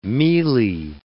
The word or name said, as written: mealie